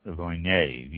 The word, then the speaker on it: Viognier: Next one, Viognier, V-I-O-G-N-I-E-R, pronounced Viognier, V-E-E-O-W-N-Y-A-Y, Viognier.